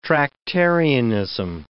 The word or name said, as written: Tractarianism